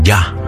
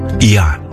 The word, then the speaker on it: Yah: Solo dicen Ya, Ya, Y-A-H, Ya.